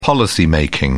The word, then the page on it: policy-making: British English